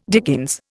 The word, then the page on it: dickens: Indian